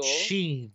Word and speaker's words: Sheen: either Shane or Shene, I'm not quite sure. – Sheen. Larry Sheen. – Like Charlie Sheen? – Exactly.